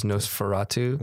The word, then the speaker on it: Nosferatu: But the way it's spelled out, NOS4A2 is Nosferatu.